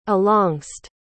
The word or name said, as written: alongst